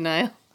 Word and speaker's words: Niall: How do you say that name? N-I-A-L-L. – Niall. – Niall? – Yeah.